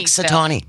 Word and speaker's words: Punxsutawney: How do you pronounce that? – Punxsutawney. – Punxsutawney. – Punxsutawney, yeah. Well, he's a local groundhog.